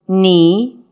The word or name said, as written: knee